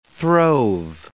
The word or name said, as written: throve